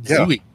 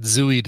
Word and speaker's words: Zooey: I think I was the person who started the Zooey trend because it's two O's instead of the usual Z-O-E for her name. So I said, I think she pronounces it Zooey. – Yeah. – Yes, she does. I'm saying it the way she says it.